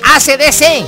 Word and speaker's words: AC/DC: Sí, de ese de AC/DC. Arriba Chiapa y Corzo, dice, pues desgraciado. – No, se llama AC/DC, nada más, bueno, que está bien, así está bien pronunciado porque en español es, pues sí, AC/DC, básicamente, como usted dice.